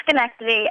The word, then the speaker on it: Schenectady: How do you say that correctly? – It's Schenectady, and I'm actually in Oneonta, New York at Hartwick College right now.